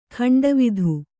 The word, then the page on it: ಗಂಡವಾತು: kannada